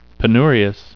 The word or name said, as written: penurious